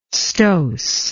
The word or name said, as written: stoss